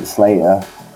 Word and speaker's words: Sl8r: So which one is it, Sl8r or is it Sl8r? Let's get that out. – Yeah, I pronounce it Sl8r.